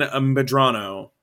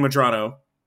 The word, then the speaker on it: Medrano: So Vivian Medrano. I got it right. Vivian Medrano.